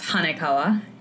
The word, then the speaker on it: Hanekawa: So it's pronounced Hanakawa, and I would never expect someone to know since it's a Japanese anime character that, I don't know, I liked.